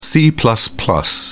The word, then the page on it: c: British English